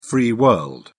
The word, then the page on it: free world: British English